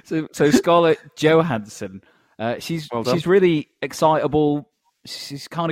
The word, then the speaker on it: Johansson: Johansson. – Johansson. – Johansson. – Oh, I thought it was a silent J. – Soft J, like yogging.